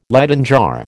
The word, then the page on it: Leyden jar: American English